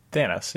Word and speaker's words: Thanos: Thanos.